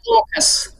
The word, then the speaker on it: AUKUS: We pronounce it AUKUS. – Oh, do you? AUKUS. Okay. – Yes, AUKUS.